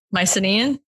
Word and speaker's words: Mycenaean: Mycenaean? – Yes.